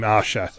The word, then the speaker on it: Ayesha: I've just started Atelier Aisha, which I'm now not entirely convinced I'm pronouncing correctly, because the Japanese voiceover pronounces her name Asha, but I think they might spell it differently as well.